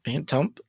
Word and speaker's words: Phantump: Phantump. Well, there's a P at the end, so Phantump. – Yeah. – So, is it a silent P? – Think of it like stump. Fan stump.